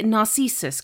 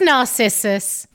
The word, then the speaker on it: Narcissus: And I'm going to say, is it Narcissus? Because you kind of say it like that in the thing. – That's very exotic, the way you said it. Just Narcissus.